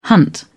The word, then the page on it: hunt: British English